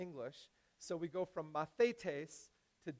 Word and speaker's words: mathetes: You have to get the, uh, the, the emphasis on the right syllable, right? Mathetes. Mathetes. It's a little hard to say because it doesn't make any sense in English.